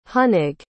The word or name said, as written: hunig